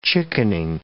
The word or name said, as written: chickening